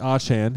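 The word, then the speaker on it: Achane: But both running back, didn't Mostert and it's actually Achan. He actually just announced today that they were saying it wrong.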